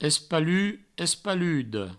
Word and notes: espalut, espaluda: provençal; espalu; espaludo

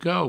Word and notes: quau: provençal

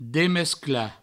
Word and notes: demesclar: Provençal; demescla

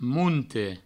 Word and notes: monte: mounte

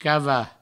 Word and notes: cavar: provençal; cava

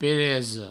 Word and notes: peresa: Provençal; pereso

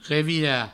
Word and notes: revirar: revira; Provençal

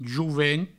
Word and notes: jovent: jouvent; Provençal

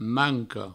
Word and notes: manco: mancou

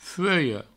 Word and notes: fuelha: fueio

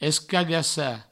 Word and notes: escagassar: escagassa; provençal

